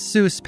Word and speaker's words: Seuss: Hans's last name was spelled S-U-E-S-S. The other doctor's name was spelled S-E-U-S-S, with the E and U flipped. Both, though, were pronounced the same way. Seuss.